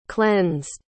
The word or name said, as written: cleansed